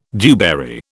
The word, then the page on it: dewberry: British English